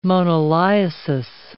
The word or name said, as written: moniliasis